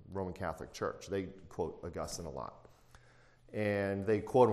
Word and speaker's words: Augustine: So, but with a lot of the work of St. Augustine, or Augustine, depending on how you want to say it, if you say it right, it's Augustine, actually.